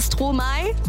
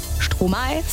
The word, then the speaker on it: Stromae: Aber ich habe zwei Eselsbrücken bei Stromae. Denken wir aber jetzt an Stroh im Mai. Stromae, Stromae, können wir uns merken.